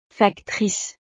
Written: factrice

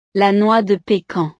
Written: la noix de pecan

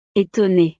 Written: etonnee

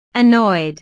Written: annoyed

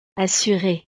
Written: assuree

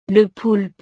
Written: le poulpe

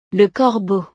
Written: le corbeau

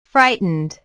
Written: frightened

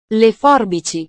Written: le forbici

